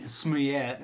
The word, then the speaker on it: Smollett: If half of the crowd was on Jesse Smollett, I can't pronounce it. – Smollett.